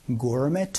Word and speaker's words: gourmet: I like going on a gourmet tour. 이거를 고르메트라고 발음하지 않고 고르메라고 발음하니까 알아두시면 좋아요. 연습 한번 해보죠.